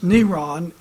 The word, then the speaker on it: Neron: The Hebrew gematria for Nero, pronounced Neron in Hebrew, is 666.